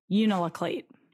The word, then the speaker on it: Unalakleet: Unalakleet.